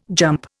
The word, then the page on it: jump: Indian